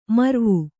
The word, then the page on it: ಮರವು: maravu